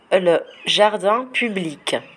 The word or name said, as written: le jardin public